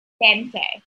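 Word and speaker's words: veinte: How do you say 20? – Vente. – Vente.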